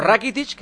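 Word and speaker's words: Rakitić: Rakitic, que se pronuncia así, Iván Rakitic, sobre cómo está ahora en el Barça.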